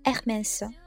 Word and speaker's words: Hermès: 爱马仕这个单词呢，在法语里面咱们要把它读成 Hermès，而在法语当中呢， H 这个字母是永远都最安静的那一个，因为它是永远都不发音的。